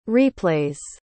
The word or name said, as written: replays